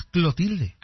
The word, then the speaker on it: Clotilde: Doña Cleotilde. – Era Clotilde, ¿no? Acá es Clotilde. – Ah, pues tal vez era Clotilde y nosotros lo pronunciábamos mal.